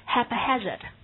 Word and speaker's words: haphazard: 根据发音，同学们可以很轻松地拼出这个词型 haphazard， so H-A-P-H-A-Z-A-R-D， the stress is on the second syllable，重音是在第二个音节上。先跟我们把它读说 haphazard， haphazard， yeah，它也表示任意的、无计划的、随意的，但这个形容词的侧重点并不是说不加选择的，而是表示没有计划的，很随意的。